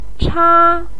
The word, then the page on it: cha1: pinyin